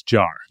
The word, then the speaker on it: Jean-Michel Jarre: Is that how you say his name? – I believe it's Jarre.